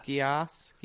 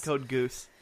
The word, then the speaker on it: Geass: Code Geass, I think it's pronounced. – Geass. Not Code Goose.